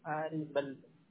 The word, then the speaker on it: Arebeldo: Solo tengo un nombre, Ariveldo Santiago Sánchez. – Santiago Sánchez, okay. Y tu primer nombre se escribe A-R-E-B-E-L-D-O, ¿así? – Sí, Ariveldo.